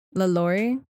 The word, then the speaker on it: LaLaurie: Delphine LaLaurie. – LaLaurie. – LaLaurie.